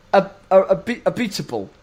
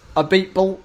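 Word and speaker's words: Abiteboul: Renault into Alpine is interesting because not only are they changing their brand, essentially, they've lost Cyril Abitbol. Have I said that correctly? – No, meat. – Close enough. – Abitbol.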